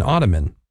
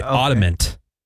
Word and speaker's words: ottoman: An ottoman. – All right. Yeah. People say ottomant. – Oh, really? – Ottomant? – Yeah, that's the most common way that people butcher that one.